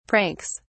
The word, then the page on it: pranks: British English